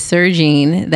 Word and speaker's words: Sergine: My name is Sergine. That is two syllables.